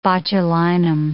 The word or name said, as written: botulinum